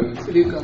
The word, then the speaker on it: Sudoku: She likes, was it Sudoku? Is that how you say it? I can never get those. What? – Sudoku. – That's the one right there.